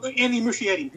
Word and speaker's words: Muschietti: Oh, Andy Muschietti.